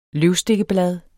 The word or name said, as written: løvstikkeblad